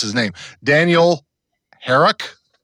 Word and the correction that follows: Harush: is that right? – Dan Harush.